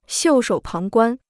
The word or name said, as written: 袖手旁观